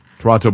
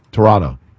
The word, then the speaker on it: Toronto: Toronto Blue Jays. – Yeah, I know. You say it incorrectly, though. – Okay, hold on. Toronto. – There you go.